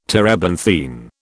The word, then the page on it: terebinthine: British English